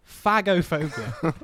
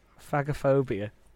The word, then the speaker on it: phagophobia: I'm going to give you the spelling after I pronounce it. – Please do. – Phagophobia. P-H-A-G. – P-H-A-G. P-H-A-G. Fag. Phagophobia.